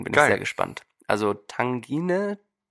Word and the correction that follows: Tagine: Tagine, ich, also T-A-G-I-N-E, könnt ihr selber mal googeln.